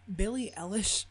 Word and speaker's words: Billie Eilish: Billie Eilish. – I don't understand why you just interrupted that moment to bring up Billie Eilish. – Oh, is that how you say it? – Yes, that's how you say it.